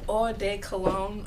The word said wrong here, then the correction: Eau de Cologne: I did not say that right at all. It's E-A-U. – Eau de Cologne. – Eau de Cologne, yes.